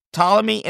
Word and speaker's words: Ptolemy: P-T-O-L-E-M-Y, Ptolemy. It's a silent P, okay?